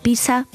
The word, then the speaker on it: pizza: En italiano se pronuncia pizza, pero la mayoría de los estudiantes la pronuncian como la ciudad, simplemente pizza.